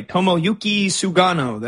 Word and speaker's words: Tomoyuki Sugano: And nice pronunciation, by the way. Tomoyuki Sugano. That's not an easy one. That's a tongue twister.